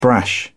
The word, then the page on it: brash: British English